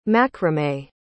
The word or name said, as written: macramé